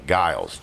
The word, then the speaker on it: Giles: His last name was Giles. G-I-L-E-S. But pronounced like the Street Fighter fighter.